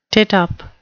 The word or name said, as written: tittup